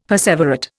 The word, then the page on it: perseverate: Indian